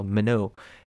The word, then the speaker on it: Minot: I thought it was called Minot, because it's Minot, so I was like, oh, Minot. Nope, it's pronounced Minot.